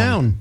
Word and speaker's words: McNown: I always say, because it's M-C-N-O-W-N, McNown. – Yes. So that is how I pronounce it and have pronounced it for my whole life. But I will say, I think the accurate pronunciation from, you know, my ancestry is probably McNown.